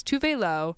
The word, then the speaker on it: Tove Lo: She's come back into the music industry in the past, like, 6 months or so and corrected all of us on how to pronounce her name. So it's Tove Lo.